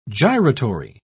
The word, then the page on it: gyratory: British English